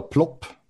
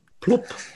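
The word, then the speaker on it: Plopp: It's called a plop. – Plop. – Yep. – And we have no vowels in English that sound like that.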